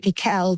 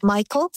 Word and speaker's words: Miquel: So my mother is Michael Brown, but spelled M-I-Q-U-E-L. Some people pronounce it Mikel, but she calls herself Michael.